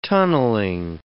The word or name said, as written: tunnelling